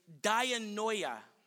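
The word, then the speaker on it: dianoia (διανοίγω): That word opened is actually the Greek word, let me pronounce it correctly, dianoia.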